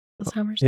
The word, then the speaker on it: Alzheimer's: Alzheimer's? – Yep.